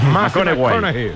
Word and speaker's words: McConaughey: Mateo McConaughey. – Sí, sí, sí, sí. – Hay que ir corrigiendo la pronunciación. – McConaughey.